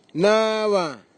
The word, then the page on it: nava: Nafe; Tanna